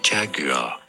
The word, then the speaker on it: Jaguar: In British English, it is said as Jaguar.